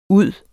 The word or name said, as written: ud